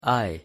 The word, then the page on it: i: English